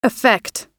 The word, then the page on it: affect: American English